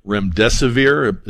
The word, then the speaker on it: remdesivir: Remdesivir.